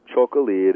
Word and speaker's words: Txakoli: It's impossible to read and pronounce because it's got T-X-A-K-O-L-I. If you try to read it, there's no way, but it's pronounced Chocolí.